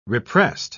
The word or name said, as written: repressed